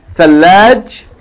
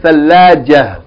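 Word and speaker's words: ثلاجة: The tarmabuta on the end will not be pronounced as ta, T, if you stop on it. But it will be pronounced as H. Thalajah.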